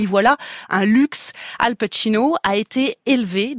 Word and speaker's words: Al Pacino: D'ailleurs, comment doit-on le prononcer ? Son nom, Al Pacino, non, Al Pacino, écrit Samuel Blumenfeld dans Le Monde.